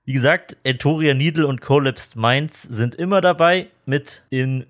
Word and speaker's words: Collapsed Minds: Wir sind im Soundcafé und noch dabei sind natürlich wir, Entoria, Needle, wovon der Eddie involviert ist und natürlich Collapsed, Collapsed Mind. Ich finde, ich habe das schon mal ausgesprochen und es ist ein richtiger Zungenbrecher. Collapsed Minds.